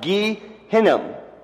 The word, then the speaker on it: Gehinnom: In Hebrew, it's pronounced Gehinnom.